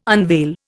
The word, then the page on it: unveil: Indian